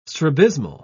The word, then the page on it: strabismal: British English